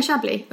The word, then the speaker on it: Chablis: Yeah, Chablis. You don't say the S, I don't think.